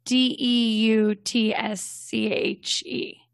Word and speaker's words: Deutsche: As it's when it started becoming more of a global bank, Americans actually called it douche bank. Oh no. Because it's spelled D E U T S C H E. So part of their their global branding when they're trying to work with Americans and English speakers, they do emphasize the pronunciation, which is Deutsche.